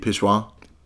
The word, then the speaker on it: pissoir: Jeg tror faktisk, det er noget med at skylle ud i et pissoir. – Nu siger du det igen på den der franske måde. Pissoir. – Ja. – Men det er flot. Flot udtale.